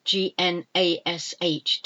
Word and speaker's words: gnash: Uh, you get dogs gnawing on a bone, gnaw, and it's spelt G N A W to gnaw something or to gnash your teeth. G N A S H, to gnash, I, I, I, to gnash your teeth, to chew something.